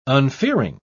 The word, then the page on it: unfearing: British English